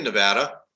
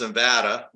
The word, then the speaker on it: Nevada: We are the only integrated delivery system in Nevada, and it's pronounced Nevada, not Nevada.